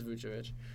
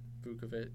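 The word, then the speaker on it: Vučević: They pronounce it differently on the broadcast, on like every broadcast. – I thought it was Vucevic. – Yeah, I think it's Vucevic.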